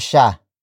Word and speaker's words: siya: You can say, Tito ko siya. Tito ko siya.